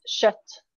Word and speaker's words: kött: Meat in Swedish is kött.